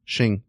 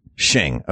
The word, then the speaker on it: Qing: Q-I-N-G. – Xing. – Xing.